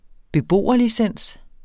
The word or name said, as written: beboerlicens